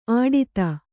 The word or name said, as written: ಆಡಿತ